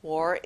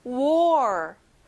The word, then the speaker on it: war: 전쟁, war. 여기는 OR, OR 발음이 나요. 다시 따라해볼까요? war.